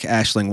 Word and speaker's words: Aisling: I wish there had been more Sarah, just because I like Aisling What's-Her-Face.